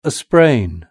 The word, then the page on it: a sprain: British English